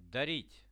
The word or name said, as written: darit